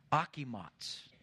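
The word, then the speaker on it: Achimots (אחימעץ): Then, and there's a pronunciation for this guy's name, and the way it would be pronounced in the Hebrew would be Achimots. Doesn't look like it, but it'd be one of those Achimots.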